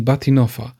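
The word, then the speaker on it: Bahtinova: Inną wersją możliwości, czy innym pomysłem na ustawienie ostrości jest skorzystanie z tak zwanej maski Batinoffa. Pisze się ten wyraz, który powiedziałem, jako Bachtinoff przez V na końcu.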